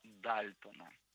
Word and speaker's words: Дальтон: Но с работой Джона Дальтона. – Я надеюсь, нет, я правильно ставлю его ударение. – Дальтон, все правильно, Дальтон.